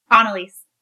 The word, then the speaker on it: Anneliese: Anneliese.